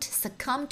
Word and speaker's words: succumb: Don't succumb to the temptation. 不要经不住诱惑。好，这个词的拼写是 S-U-C-C-U-M-B. Succumb. Succumb. B 是不发音的。